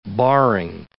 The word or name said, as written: barring